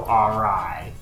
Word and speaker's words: awry: and things go awry. – Awry, because that's how you pronounce that word.